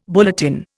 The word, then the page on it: bulletin: Indian